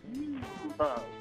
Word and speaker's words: Bimbo: It's pronounced Bimbo, but it's Bimbo.